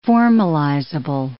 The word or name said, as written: formalizable